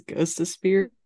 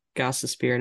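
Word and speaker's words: Gostisbehere: I read it as Ghost of Spear because his nickname is Ghost, but it's not Ghost of Spear. It's Gossesphere,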